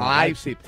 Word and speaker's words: Leipzig: Lo he dicho bien, Leipzig. O Leipzig. Se dice casi un poco con Leipzig.